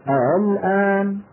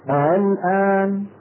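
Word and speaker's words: آلآن: وبعض القبائل العربية غيروا هذه الهمزة الثانية وهي همزة الوصل، أبقوها لم يحذفوها ولكن غيروها ليس بالإبدال وإنما بطريقة أخرى هي التسهيل، هي التسهيل. نطقه هكذا الآن الآن، نعم.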